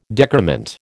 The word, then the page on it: decrement: American English